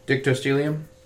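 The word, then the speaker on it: Dictyostelium: How's that word pronounced again? – Dictostelium. – Oh, you say it so well now. Dictostelium. – Well, I watched a YouTube video on how to say it, so...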